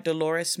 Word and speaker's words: Dolores: And so again, in this Dolores, and am I pronouncing that right, Dolores mission? – Yeah.